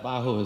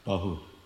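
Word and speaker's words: Bahu (बाहु): So that Bahu is different, that's short A, Bahu, Bahu.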